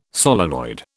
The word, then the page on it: solenoid: British English